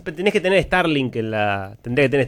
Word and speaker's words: Starlink: Lo pronuncié mal para Pesci igual. – Starlink. Pero no te voy a juzgar. Starlink.